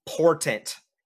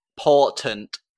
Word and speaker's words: Portent: Norbit, with the golden foon, you're going to get an ability called Porton Rolls. – Oh my fucking god. – Can you tell me how to pronounce that one? – Portent. – Portent. – P-O-R-T-E-N-T. – Portent. Yep. Portent.